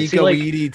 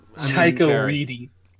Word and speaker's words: Taika Waititi: Taika Waititi taking this on because... – Taika Waititi. – I'm sorry. My pronunciation. This is the reason we have one star on iTunes. – Taika Waititi.